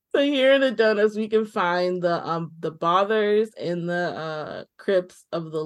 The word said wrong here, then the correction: Bothros: I mispronounced that. Not the Bothers, the Bothros.